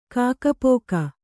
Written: ಕಾಕಪೋಕ

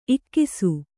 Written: ಇಕಿಸು

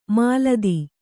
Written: ಮೆಲ್ಲಿದು

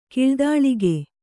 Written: ಕಿಳ್ದಾಳಿಗೆ

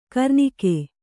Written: ಕರ್ನಿಕೆ

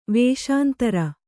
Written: ವಸುಂಧರೆ

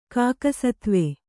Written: ಕಾಕಸತ್ವೆ

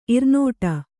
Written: ಇರಿನುಡಿ

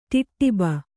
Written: ಟಿಟ್ಟಿಬ

